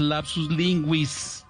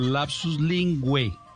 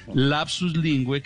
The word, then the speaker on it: lapsus linguae: Eh, bueno, Marta, y, a los que lo dicen en radio así, no es lapsus linguis, sino lapsus lingüe. Eh, se escribe ah, ah, linguae. En la, eso es latín, ¿no? Lapsus linguae, y se pronuncia, la pronunciación más recomendable es lapsus lingue, que quiere decir error, error involuntario al hablar.